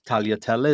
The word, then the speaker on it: tagliatelle: Yeah, they'll say tag. We'll have tag pasta or tag. And it's like, no, that's taglia means to cut. And this is cut pasta that's cut in lines, in long lines. Tagliatelle is how you say it.